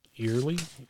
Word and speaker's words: Eyler: E-Y-L-E-R. How do you say that? – Eerly.